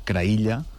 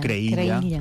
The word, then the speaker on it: Creïlla: No hi cauré ara, No, que és Creïlla. – Creïlla. – O Creïlla, pronunciat a la valenciana.